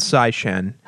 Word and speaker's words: Tsai Shen: It's called Sai Shen. – How do you spell that? – T-S-A-I-S-H-E-N.